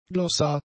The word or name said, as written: Glossar